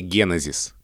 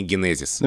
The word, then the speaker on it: генезис: Смотри, после ударного слога три безударных. Это жутко неудобно для языка. И поэтому, как бы мы этому не противились, а язык толкает нас в сторону ударения генезис.